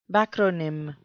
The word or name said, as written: backronym